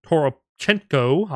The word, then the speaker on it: Toropchenko: Toropchenko, I'm going to butcher the shit out of the name. – You nailed it. You nailed it. – Really? Holy crap. Toropchenko. – Okay.